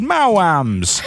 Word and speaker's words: Maoam: C-U-M? – Okay, so these are delicious treats from the UK. They're called Mowams. – How do you spell that? – M-A-O-A-M.